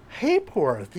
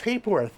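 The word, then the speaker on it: ha'porth: Hepworth, yes. – Hepworth, is that how you say it? Okay. – Yes, Hepworth, like Haypenny. Haypenny, Hepworth.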